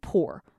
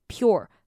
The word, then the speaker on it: pure: 好，这个呢，我小时候在看的时候哈，因为那时候还不太熟悉这个 u r， u 跟后面是 e 的这个规则，所以我都会把它念成 poor。哦，这样就 poor 就很穷，就不是这个字嘛。所以你看到这个的话，你就把它想成 your。 Your，你把后面那个 u r e 把它想成是 your，就是你的那个 your，把它念成 your pure，这样念起来就会相当的标准了哈。那这个 sugar 这边也是跟前面那个 sugar 一样的规则。